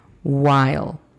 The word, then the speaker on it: while: La pronunciación fonética de esta es U-A-I-E-L. While.